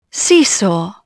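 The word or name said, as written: seesaw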